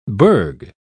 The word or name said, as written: burgh